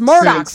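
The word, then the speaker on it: Murdaugh: It's Murdoch. See, I didn't even know how to say it. I was like, D-A-U-G-H. That's Murdoch. It's Murdoch.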